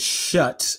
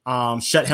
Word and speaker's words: Schutt: I believe the pronunciation is shut. I could be wrong. I could have been pronouncing this wrong for my entire life, but I always believed they were shut helmets. S-C-H-U-T-T.